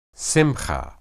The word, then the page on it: simhah: Hebrew